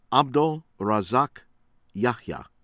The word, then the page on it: Abdel Razak Yahiya: American English